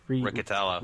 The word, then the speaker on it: Riccitiello: How do you say his last name? Riccitiello? – Riccitiello.